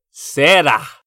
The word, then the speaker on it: será: Será. – Será. – Será. – Ajá. Con el acento en la parte final.